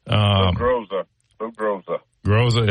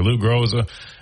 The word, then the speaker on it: Lou Groza: I thought at least Will Reichard would get the Lou Garza Award or however you pronounce it. – Lou Groza. – Yeah, sorry. Lou Groza.